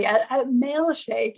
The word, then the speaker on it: Mailshake: I always think milkshake when I hear Mailshake, and I'm glad I didn't spit that one out. – So I have to say it like slowly. Mailshake. Yeah. So at Mailshake, like you're sending mail.